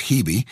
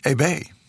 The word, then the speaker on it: Hebe: 926 at APR, I'm David Duff, and her name is, in English, often pronounced Hebe, in French, Hebe.